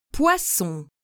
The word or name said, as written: poisson